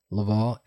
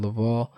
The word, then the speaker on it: Laval: My name at Javier Laval, LAVAL, palindrome spelled the same backwards and forwards. Javier Laval.